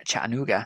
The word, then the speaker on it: Chattanooga: Now, the next up over in Ironman Chattanooga, which we traditionally pronounce with a Geordie accent for some reason, Chattanooga.